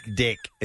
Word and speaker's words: Deck: Dick. It's D-E-C, so I pronounce it like Dick.